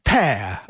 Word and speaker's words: pair: And of course it is spelt P-A-I-R and pronounced pear, of course. So pear, pareja, par, and pera, pear as well, sound exactly the same.